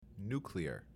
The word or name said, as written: nuclear